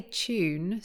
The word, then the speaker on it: tune: So yeah, I say tune. So in the UK, when you have a T and a U together, we tend to make it more like a ch sound.